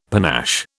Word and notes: panache: British English

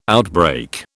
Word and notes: outbreak: British English

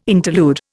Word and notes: interlude: Indian

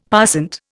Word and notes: passant: Indian

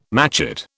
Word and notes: matchet: British English

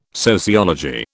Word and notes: sociology: British English

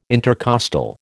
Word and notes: intercostal: American English

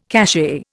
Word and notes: cachet: Indian

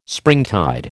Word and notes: springtide: American English

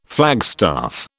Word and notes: flagstaff: British English

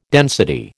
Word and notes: density: American English

